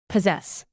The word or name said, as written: possess